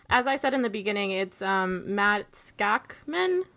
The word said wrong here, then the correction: Shackman: or Shackman, sorry. I pronounced it wrong at the beginning. Shackman. Okay. Or, sorry, Matt Shackman.